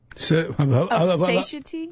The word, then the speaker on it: satiety: Satiety? – Yeah, well, no. It's, or satiation? – Well, it's satiation. I believe it's pronounced satiety, though.